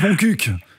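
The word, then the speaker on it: Montcuq: Ça se prononce Moncuq, en fait.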